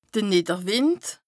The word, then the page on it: de Nìdderwìnd: Bas-Rhin; Strasbourg